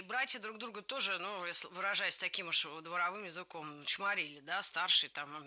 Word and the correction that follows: дворовым: Дворовым языком – это неверно, правильно, дворовым. В прилагательном, образованном от существительного «двор», ударение ставим на второй слог.